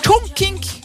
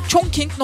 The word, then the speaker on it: Chongqing: Chongqing Normal Üniversitesi'nde aynen böyle. Chongqing